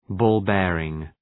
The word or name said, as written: ball bearing